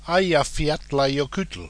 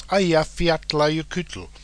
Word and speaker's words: Eyjafjallajökull: The name of the volcano is Eyjafjallajökull. That's Eyjafjallajökull, which means in Icelandic, Island Mountains Glacier. I don't speak much Icelandic, but I do speak some Norwegian, and trust me, that is a fairly good pronunciation, so you can go off and, as I say, impress your friends with Eyjafjallajökull.